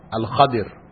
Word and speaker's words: Al-Khadir: What you find common in this chain is that Al-Khidr is mentioned. Al-Khidr, right? Al-Khidr, or his proper name is Al-Khadir. Right? That's the proper pronunciation, but people know him commonly as Al-Khidr.